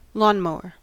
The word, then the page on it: lawnmower: American English